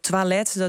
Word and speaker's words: toilet: Toilet, de OI spreek je uit als een twa.